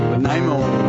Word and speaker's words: Nanaimo: Nanaimo.